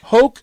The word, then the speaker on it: Hok: Hawk, Sweden.